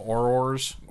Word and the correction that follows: auras: Auras. – Auras, okay.